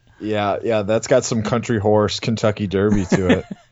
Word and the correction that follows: Country House: Or country house.